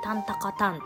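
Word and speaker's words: 鍛高譚: で、タンタカタンって読むんですけれども、あの、北海道の焼酎らしいですね。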